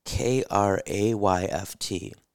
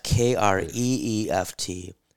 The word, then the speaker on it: Kreeft: It says, his last name is pronounced K-R-A-Y-F-T, not K-R-E-E-F-T or K-R-E-H-F-T.